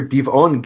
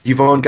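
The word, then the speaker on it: Dibon: And, you know, you can, you know, Divon God to this day is called, you know, Diban, and, you know, same exact word, just with an Arabic pronunciation, or Diban.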